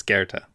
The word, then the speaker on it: Goethe: What did I say? – You just said Goethe. It's Goethe. – How did you say it? – Goethe. – Like it's spelled? – Goethe. It's not Goethe. – It's a German, okay. And there's an umlaut in there, which we don't use because we anglicize it.